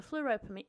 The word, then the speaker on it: Fleurieu: Some pronounce it Fleurieu, some say Fleurieu, but it's the Fleurieu Peninsula, so put a sentence together, in South Australia, just south of, south of the border, south of the capital of Adelaide.